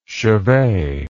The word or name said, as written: chevee